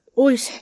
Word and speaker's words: 大磯: はい、出身地は、ご出身地は、神奈川県大磯町って読むんですかはい。大磯。そうです。大磯町です。